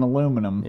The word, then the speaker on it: aluminum: You've been in this country so long, now you're saying aluminum. – Yeah. – So we're converting you. So we're slowly converting you. So that's good to hear you pronounce that word correctly.